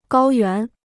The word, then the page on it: 高原: gāo yuán